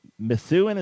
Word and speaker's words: Methuen: Matthew in Methuen, is that how you pronounce it, Massachusetts? – It is, yeah.